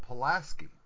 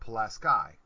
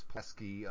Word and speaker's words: Pulaski: P-U-L-A-S-K-I. S-K-I is ski. Whether it's the English language or the Polish language, it's ski. But no, up here it's sky. Unless you're going down the slopes, because you're not skying down the slopes, you're skiing. they don't understand that difference. It's Pulaski up here. It's Pulaski, New York.